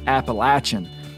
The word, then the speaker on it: Appalachian: Like me, she's particular about the pronunciation of words, specifically the word Appalachian, and she considers it a non-negotiable.